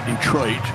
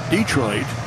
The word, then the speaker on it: Detroit: Some people say Detroit. Some people say Detroit. Emphasis on first syllable.